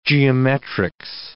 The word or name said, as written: geometrics